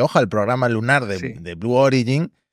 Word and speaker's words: Blue Origin: A ver, desde que dejó Amazon, ya hemos dicho que ha pegado el acelerón Blue Origin, que por cierto, qué bien lo pronuncias. En este podcast somos de pronunciar muy mal las empresas. Estamos intentando pronunciar bien Agianspace. – Hostia. – ¿Vale? Porque solemos pronunciarlo, sobre todo yo, fatal. Pero lo de Blue Origin, con el acento bien puesto, me lo voy a apuntar.